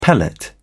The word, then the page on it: pellet: British English